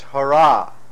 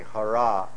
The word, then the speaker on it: Hara: And the word Hare, now in the root form, it's Hara, H-A-R with a long A, Hara.